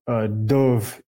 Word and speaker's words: dubh: Well, yeah, like a dove in the Celtic means black. It would be spelled D-U-B-H. They pronounce that B-H like a V, I believe.